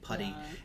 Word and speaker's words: putty: He says putty. Like, he pronounces the T's. It's not putty. It's putty. – Yes, it's not like, yeah, it's putty. Not with two D's.